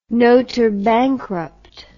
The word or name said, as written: notour bankrupt